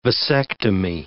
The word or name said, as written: vasectomy